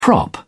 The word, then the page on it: prop: British English